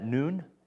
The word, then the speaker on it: nun: That N-U in there, that nun, that noon, is actually how it's said, that's actually a Hebrew letter.